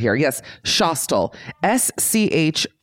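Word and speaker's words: Schostal: called Shostel. I believe. Like Eli Roth's Hostel.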